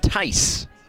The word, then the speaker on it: Theis: Spelled T-H-E-I-S, pronounced Tice.